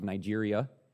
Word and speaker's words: Niger: If you notice, Simeon was called, well, it's mostly people would pronounce it Niger. I think that's because of Nigeria. The term means black man, but the pronunciation may offend you. So will you just brace yourself here while I traverse a social taboo? The way to pronounce it is Niger.